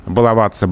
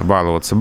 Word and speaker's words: баловаться: Да, все знают, что правильно баловаться балуешься, но распространенность варианта баловаться балуешься э, лингвисты не могут игнорировать. И поэтому сейчас э, в некоторые словари ударение «баловаться» уже попало как допустимое в разговорной речи.